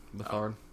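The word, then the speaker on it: Beathard: Bethard. It's spelled beat hard.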